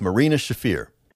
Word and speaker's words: Marina Shafir: Yes, Marina Shafir.